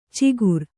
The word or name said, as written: ಚಿಕುರ